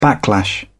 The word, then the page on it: backlash: British English